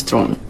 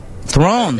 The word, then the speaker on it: Theron: Pronunciation of my last name is Thron. – Thron.